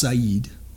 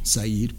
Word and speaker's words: Sidon: So it is a Phoenician word, Sidon. It's pronounced Said in their actual language. Said, Port Said, in their language, and it does mean fishing port, and in their language, and so it is interesting.